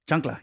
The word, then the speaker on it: chancla: Flip-flop. Chacla. – Chancla, yeah. – Chancla.